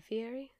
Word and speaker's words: Fihri: Fury as in like Guy Fury? – All times I've heard it pronounced, it's Fury as in like Guy Fury. – Get the fuck out. – But it's pronounced, it's written out F-I-H-R-I.